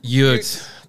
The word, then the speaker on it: Ute: Did I say it wrong again? – Ute.